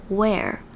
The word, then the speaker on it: wear: 但是 ea 不一定发 e，它还有另外一个发音，就是 a，像是穿衣服的穿 wear， wear 也是 ea，但是它是发成 a，而不是这边 peak 的 e 的声音。